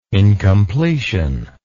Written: incompletion

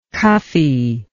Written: cuffe

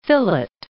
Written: fillet

